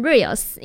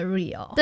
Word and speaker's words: Real: 对，没错， Real 这一首这个专辑都一直让我想要念成 Reels，因为呢最近就是 IG 滑太多了，我就一直很常念成它是真实的 Real。 – 对，它不是 R-E-E-L 也不是 R-E-E-L-S，它是 R-E-A-L，真实这样子。